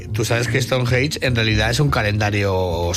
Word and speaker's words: Stonehenge: Stonehenge.